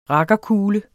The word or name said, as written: rakkerkule